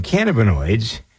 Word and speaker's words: cannabinoids: And so we have, with this research that's being done, opened the door for developing pesticides from cannabinoids, Cannabinoids, yeah, that's how you pronounce it.